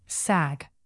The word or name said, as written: sag